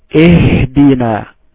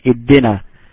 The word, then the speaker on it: اهدنا: I like people to pay attention and to stress on the first word, the first two letters. Make sure that you have ihdina. This is ihdina. Never say iddina. Ihdina.